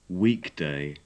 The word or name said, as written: weekday